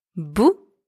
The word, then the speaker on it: bout: Bou spelled B-O-U-E means mud. Bou spelled B-O-U-T means tip or piece or end. So in these two words, the letter B is pronounced B and the letters O and U together are pronounced OU. And the final letters E and T are silent.